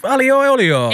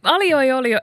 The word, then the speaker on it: aglio e olio: Aglio, meaning garlic. In Italian, olio. Aglio e olio.